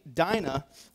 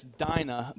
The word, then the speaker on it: Dinah: It says, "Now Dinah," I'm going to mispronounce that. It's Dinah, but I've been thinking Dina. Every time I read this, I'm thinking Dina.